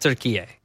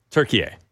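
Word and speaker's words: Türkiye: They pronounce it turkey A. – Turkey A. – Okay, like, so over we'll there, it's pronounced turkey A.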